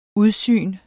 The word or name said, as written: udsyn